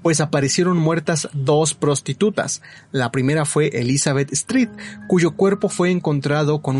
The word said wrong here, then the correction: Stride: Ah, no, perdón, lo, pronuncié mal, es Stride. Ah, Stride. Stride. – Ah, okay. Sí, dije Street.